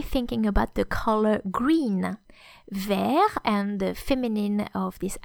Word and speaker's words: vert: Therefore, the masculine form has a final silent T, which you don't hear in the masculine, vert, but you hear in the feminine, verte. So, V-E-R-T or V-E-R-T-E for the feminine is the adjective green.